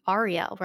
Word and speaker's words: Arielle: And I would just love to ask you, how do you pronounce A-R-I-E-L-L-E? – I was going to ask you the same question, so this is perfect. I go Arielle, but I think you do Arielle, right? – No, I do Arielle.